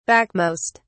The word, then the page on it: backmost: British English